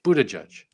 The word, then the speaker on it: Buttigieg: No wonder it's like Buddha and Judge. Buttigieg.